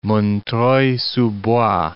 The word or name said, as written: Montreuil-sous-Bois